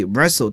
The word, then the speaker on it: wrestle: 接下来的两个词是和奥运会的比赛项目相关的，一个是摔跤 Wrestle 大家注意这个词啊 WR 开头，那么往往有时呢这个 W 是不发音的，比如说这个吹哨子 Whistle 啊，像这里 Wrestle W 都是不发音的，在英语中被称作 Unvoiced 啊，不发音摔跤。